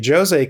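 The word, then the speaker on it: José: And yes, I said Jose because we all got schooled on Twitter this week about the correct pronunciation of Jose being Brazilian versus versus Spanish, which is Jose, because Portuguese he's versus Spanish. – Exactly, because he's Portuguese, he's Jose, he's not Jose.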